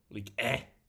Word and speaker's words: Ligue 1: How do you say it again? Say it for me. – Ligue 1. – There you go.